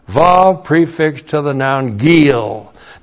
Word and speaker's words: gil: And then we have gil. G long I L. Gil.